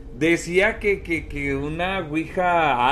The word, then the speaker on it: Ouija: Según la grafía recomendada por el RAE, la Ouija es del francés oui, que significa sí, y del alemán ja, pronunciando Ouija.